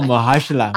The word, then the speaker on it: Mahershala: I can never say his name. Mar Marhashala, Marshala. – Oh, Mahershala.